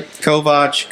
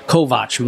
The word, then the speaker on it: Kovach: Did I pronounce it right? – Kovach, Mario. – How do you pronounce it? – Kovach, Mario Kovach.